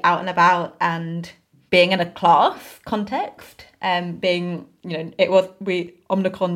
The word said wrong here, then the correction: Omicron: didn't feel, is it Omnicron? I feel like Omicron? – I say it wrong each time. – Mr. Omicron. Omicron.